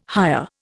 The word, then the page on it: hire: Indian